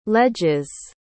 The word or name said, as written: ledges